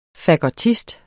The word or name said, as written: fagottist